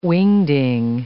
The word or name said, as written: whing-ding